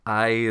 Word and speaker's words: Ai: We have Ai, as it's pronounced, and we have all that Joshua goes on to do.